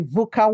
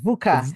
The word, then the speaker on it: VUCA: Yeah, it's actually pronounced the VUCA world, VUCA.